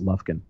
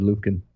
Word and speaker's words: Lufkin: I think it's pronounced Lufkin, by the way. – Didn't I say Lufkin? – No, you said Lufkin.